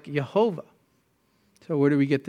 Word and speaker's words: Jehovah: Well, in German, when you translate the Yah sound, the Yod, it's a J. It's a J sound. So Jehovah. Because you take Yahweh, you pronounce the Yah as a Jah, and you put the vowels from Adonai, Jehovah.